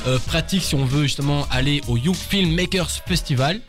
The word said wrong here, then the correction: Young Filmmakers Festival: Alors, le Young Filmmakers Festival, avec toutes les syllabes évidemment.